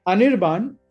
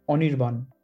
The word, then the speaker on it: Anirban: Anirban in English, Germans stress the I part, but in Bangla, it would be Anirban. The A is basically AW. The first A is always AW.